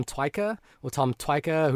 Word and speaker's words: Tykwer: Tykwer, who directed Run, Little, Run. – I think Tykwer. – Tykwer, is that how you say it? – I think that's it.